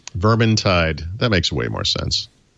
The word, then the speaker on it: Vermintide: Vermintide. So it's Vermintide, dude.